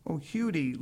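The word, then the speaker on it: Huddie: The chapter on Lead Belly or Huddle, pronounced Hudie Ledbetter, tells a tangled story that is not easily summarized.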